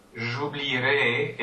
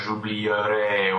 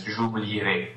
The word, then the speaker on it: j'oublierai: But when you use it in the future tense, that E stays there, but it just becomes silent. – Yeah. – And it's pronounced j'oublierai. – Yeah, so we only actually say that the lowercase phonetic I. – Don't try to do a j'oublierai or something like that. It's j'oublierai.